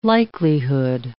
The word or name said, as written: likelihood